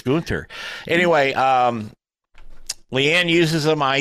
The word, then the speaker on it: Gunter: Did I say that right this time, Leanne? Gunter? – Yep. – Yeah. Okay. Okay. – Gunter. – Gunter, Gunter, it's Gunter.